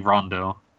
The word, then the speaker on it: Ronde: Also, it's spelled R-O-N-D-E, but the written English below it spells it, like, assures you the pronunciation is apparently Rondo.